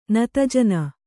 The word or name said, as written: ನತಜನ